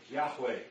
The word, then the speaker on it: Yahweh: So, in the Hebrew tradition, here was the word Yahweh, and that's how it would be pronounced.